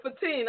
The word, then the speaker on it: Fatin: And Fatin, I said your name right? – Baby, you got me.